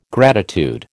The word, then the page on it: gratitude: American English